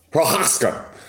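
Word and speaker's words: Prochazka: Prochaska. That's how it pronounced. Prochaska.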